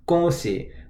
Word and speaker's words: 恭喜: The first part of the phrase is 恭喜, which is spelled G-O-N-G in the first tone, 恭, and then X-I in the third tone, 喜.